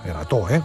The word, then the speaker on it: Erato: Y es que llega la novena edición del Erato Fest, Erato, con acento en la O.